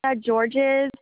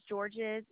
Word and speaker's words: Georges: It's Leah Georges. It's spelled a little different. It's LEIGHA. And the last name's Georges, GEORGES.